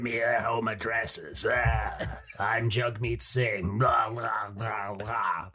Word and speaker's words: Jagmeet: Jagmeet.